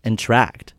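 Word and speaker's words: Entr'acte: But Entr-act? – Yeah. – Entr-act? – Entr-act. – Entr-act. Like to interact.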